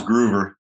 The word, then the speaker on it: Grover: So first of all, you've got that cool name, G-R-O-O-V-E-R. How do you really pronounce it, Marty? – Just like it sounds, Grover.